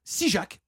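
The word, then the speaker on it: Issigeac: dans le petit village d'Issijac, Sijac se prononce en occitan, euh, situé à deux encablures des vignes donnant euh, au monde entier le mont Basiliac.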